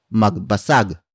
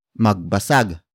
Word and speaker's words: magbasag: If the focus of the sentence is at the person performing the action, mabasag becomes magbasag. Magbasag. Magbasag. Magbasag is spelled as M-A-G-B-A-S-A-G.